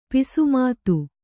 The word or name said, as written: ಪೂಸಿಮಾತು